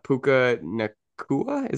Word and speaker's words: Puka Nacua: I believe he's wide receiver three or four in ESPN rankings, but I'm gonna go with Puka Nacua.